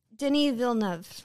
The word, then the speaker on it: Denis Villeneuve: My God, like, is it Denis Villeneuve? I know I'm going to say it wrong. Or Denis Villeneuve. – Villeneuve? Mm-hmm. Okay, Denis Villeneuve.